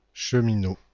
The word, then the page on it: cheminot: [ʃəmino]; /ʃə.mi.nɔ/; /ʃmi.no/